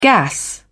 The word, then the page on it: gas: British English